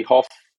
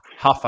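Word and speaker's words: Hough: So if you do decide to hold him, but you only have the likes of, Ward or Stevens, or I've got Brady Hoff. Is that how you say it? Huff, I think.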